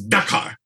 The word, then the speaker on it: Dakar: I used to misspell it. I used to say Dakar. It's like, no, it's Dakar.